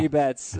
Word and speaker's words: Mookie Betts: His name rhymes with Kookie Metz. Yes, yeah. Mookie Betts.